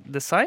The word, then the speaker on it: Desai: Resad Desai, eh, did I say it right? – That's correct.